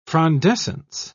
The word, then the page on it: frondescence: British English